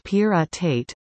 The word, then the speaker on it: pyrrhotite: Pyrrhotite, pronounced pyrrhotate, is an iron sulfide material containing low levels of nickel, cobalt and copper.